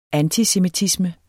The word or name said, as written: antisemitisme